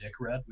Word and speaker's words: Decred: So Ren, Zcash, because I'm getting Decred feels on that, actually Decred, I called it Decred for a long time. In Korean, it's actually pronounced Dickred, which is pretty funny.